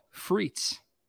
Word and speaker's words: Frites: The Idaho Potato Commission is behind this new scent called Freets. And actually, I'm sorry, spell it. – F-R-I-T-E-S.